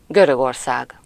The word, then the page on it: Grekland: [ˈgreːk.land]